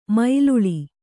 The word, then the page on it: ಮೇಲಾಳ್: mēlāḷ